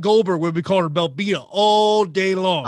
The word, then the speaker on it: Belbita: It looks like Belbitsa. That's not how it's pronounced. – Yeah. – I said it's like pizza at the end. Belbitsa. – Belbitsa.